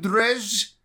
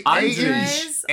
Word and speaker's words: Andrzej: Andrzej Sapkowski.